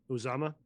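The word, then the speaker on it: Uzomah: And honestly, they have a legit tight end in CJ Uzman, I think is how it's pronounced. – Uzama. – I mean, Uzama, thank you.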